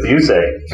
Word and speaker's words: Busiek: I'll add the pronunciation, too. It's Busiek. – Sorry, Busiek.